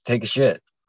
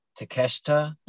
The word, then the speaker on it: Takeshita: No, it's Take a Shit. Like, I'm checking it out. – It's like Hiroshi. No, I'm telling you, it's Hiroshi Takeshita. Is how they say it, Takeshita.